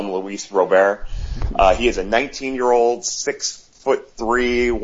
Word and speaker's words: Robert: The last name is pronounced Robert.